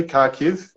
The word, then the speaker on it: Kharkiv: Am I saying that correctly, Kharkiv? – Yeah, you're saying it right.